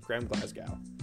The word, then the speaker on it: Graham Glasgow: So this also, Graham Glasgow. I don't know why I mispronounced that so bad in the first one.